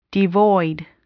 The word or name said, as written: devoid